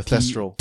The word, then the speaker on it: Thestral: Tristal. – One. Well, technically, it's just a... what you said was kind of correct. It's just a pronunciation. – Yeah. – So it's a Thestral. – Thestral.